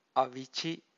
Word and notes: avici: Pali

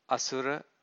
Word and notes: asura: Pali

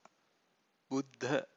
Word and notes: buddha: Pali

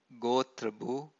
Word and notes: gotrabhu: Pali

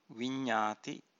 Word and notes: vinnati: Pali

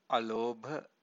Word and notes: alobha: Pali

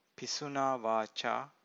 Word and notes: pisunavaca: Pali

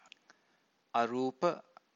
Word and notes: arupa: Pali

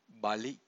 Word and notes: bali: Pali

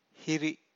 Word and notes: hiri: Pali

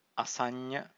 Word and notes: asanna: Pali

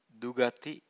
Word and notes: dugathi: Pali

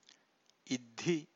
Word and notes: iddhi: Pali

